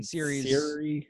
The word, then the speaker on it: Ciri: Geralt and Ciri. – Ciri, what's her? Ciri. Cyril? How do you pronounce her name? – Like your phone.